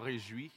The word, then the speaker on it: Réjouis: So again, my name is Davidson Jean Réjouis.